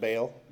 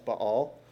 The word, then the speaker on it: Baal: Now, Judah had worshipped, and in Southern Baptist Church, we call it Baal, but it's actually pronounced Baal, and Asherim.